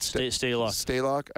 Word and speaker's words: Stalock: Stalock.